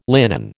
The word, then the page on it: linen: American English